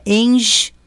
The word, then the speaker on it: Engie: No Instagram é Engie, o G é mudo, né?